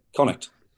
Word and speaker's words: Connacht: Isn't it Connacht? – Connacht.